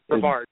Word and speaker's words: Brevard: I keep on saying that wrong, don't I? – Brevard. – Is it Brevard? – Brevard, yeah, Brevard College. – Brevard, yes, sir.